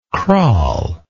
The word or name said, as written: craal